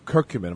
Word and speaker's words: curcumin: And I found a company that is now manufacturing a form of curcumin. Am I pronouncing it right? Is it curcumin? – Curcumin.